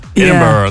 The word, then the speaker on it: Edinburgh: Is it Edinburgh? – It's spelled like Edinburgh. Edinburgh. Yeah. Like, there's no borough. It's like Edinburgh. – I think I might be pronouncing the bruh too much.